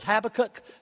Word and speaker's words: Habakkuk: Listen to the words of Habakkuk, or even the newer pronunciation, some of it is Habakkuk, so whichever you like, great. It was Hebrew, nobody knows how to say it anyway.